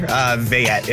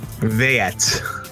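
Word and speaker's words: Veillette: That's definitely a color. Vayette is how you pronounce my last name. – Violet? – Vayette.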